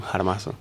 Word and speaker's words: jarmazo: Sí, la J débil, un jarmazo. – Exactamente, J débil. Se escribe con J, pero es con J débil.